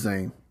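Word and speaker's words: Zhané: I want to say Zane, but that's not how you say it. It's supposed to be like Zane or something. – No, it's Zane.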